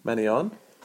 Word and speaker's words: Mannion: Mannion? That's how you say it, right? – Mannion.